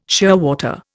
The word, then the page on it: shearwater: Indian